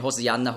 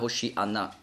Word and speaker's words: Hosianna: Ja, also das einfach zur Erklärung, warum diese Unterschiede, Hosianna, Hoshianna, das ist das Gleiche, nur unterschiedlich ausgesprochen.